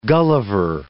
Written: Gulliver

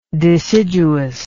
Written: deciduousness

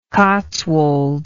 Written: cotswolds

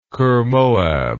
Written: kirmoab

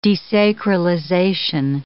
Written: desacralization

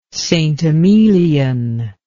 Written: St. Emilion

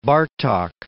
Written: Bartok

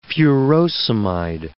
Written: furosemide

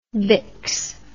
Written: bix

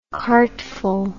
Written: cartful

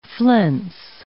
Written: flense

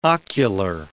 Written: ocular

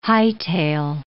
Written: hightail

